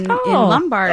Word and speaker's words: Lombard: You must be from Lombard, right? Because only the people in Lombard pronounce it Lombard. – Yeah. Otherwise, it's Lombard.